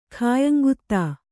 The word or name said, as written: ಖಾಯಂಗುತ್ತಾ